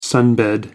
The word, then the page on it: sunbed: American English